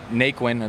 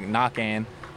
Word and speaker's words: Naquin: So it's, people say it Naquin, or they attempt Naquin, but the real pronunciation is right. Joey was saying, you know, I told him that it's Knockan, and it's just Cajun, you know, it's just French. So it's really got like Knockan.